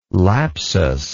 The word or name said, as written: lapsus